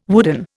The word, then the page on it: wooden: Indian